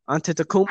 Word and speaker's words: Antetokounmpo: Antetokounmpo. – How you can say that? – Antetokounmpo.